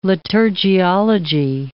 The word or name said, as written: liturgiology